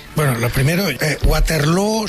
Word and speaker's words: Waterloo: Pues no lo es, desde luego, pero ha hecho mucho daño en la pronunciación de Waterloo, que se pronuncia igual que Feijóo.